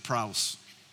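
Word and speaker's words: praus (πραΰς): I'm sure I'm not getting that right, praus. Praus. Any Greek scholars in the room know how to pronounce this? Good. Believe me, that's praus.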